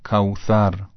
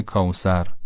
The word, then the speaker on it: کوثر: مثلا کوثر به جای کوسر، نوک زبان یه مقدار که از بین دندونها بیاد بیرون تلفظ درسته. انا اعطینا کل کوثر، کوثر، به همین راحتی.